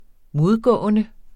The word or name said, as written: modgående